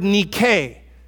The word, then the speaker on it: nike: It's the word nike. And then different forms are used in the way it's translated here, but the root word is nike. You would say it like this, Nike.